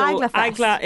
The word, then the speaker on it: Eagla: So where did the idea for this festival, because it's, how do you pronounce it? – Eagla Fest. So Eagla is Irish for fear.